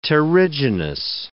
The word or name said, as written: terrigenous